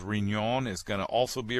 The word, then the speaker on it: Riñon: And then Riñon, R-I-N-O-N. R-I-N, that middle N has a little tilde over it, the little squiggle on top of the N, which means you pronounce it Riñon, but it's R-I-N-O-N, Riñon tomato.